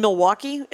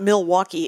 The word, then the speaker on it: Milwaukee: And Milwaukee is not Milwaukee, it's Milwaukee. No L.